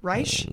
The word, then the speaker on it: Reich: I'll spell it R-E-I-C-H. Reich? Reich? – Right. – Reich.